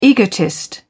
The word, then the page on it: egotist: British English